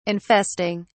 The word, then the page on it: infesting: British English